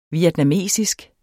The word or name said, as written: vietnamesisk